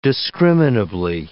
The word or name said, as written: discriminably